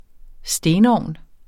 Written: stenovn

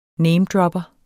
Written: namedropper

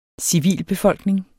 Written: civilbefolkning

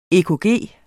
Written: EKG

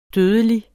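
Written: dødelig

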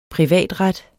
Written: privatret

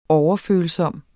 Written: overfølsom

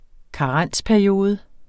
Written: karensperiode

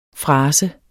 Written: frase

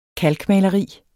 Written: KALKMALERIS

